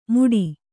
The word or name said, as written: ಮೂಡಿ